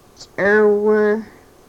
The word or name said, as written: srwrh